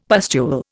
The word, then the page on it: pustule: Indian